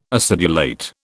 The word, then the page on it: acidulate: British English